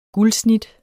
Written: guldsnit

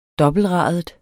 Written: dobbeltradet

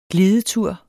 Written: GLIDETUR

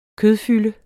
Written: kødfylde

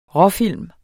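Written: RÅFILM

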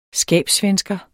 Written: skabssvensker

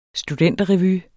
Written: studenterrevy

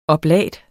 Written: oblat

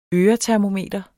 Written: øretermometer

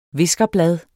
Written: viskerblad

